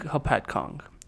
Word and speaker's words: Hopatcong: Hopatcong in New Jersey.